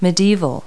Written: medieval

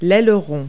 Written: aileron

